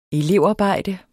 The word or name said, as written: elevarbejde